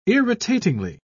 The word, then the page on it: irritatingly: British English